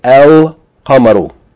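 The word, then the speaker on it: El Qamaru (القمر): So if a word begins with a moon letter, like Qaf in the word Qama, then if you add Aleph Lam, the Lam is going to be pronounced, and you're going to say El Qamaru.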